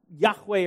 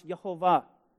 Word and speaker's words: Yahweh: It is the Tetragrammaton, the sacred name of God, Yahweh, or some pronounce Yehovah.